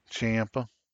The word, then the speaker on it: Ciampa: Ciampa? – Yep. – Ciampa? Is that how you say it? Ciampa? – Champa. – I'm always saying it wrong.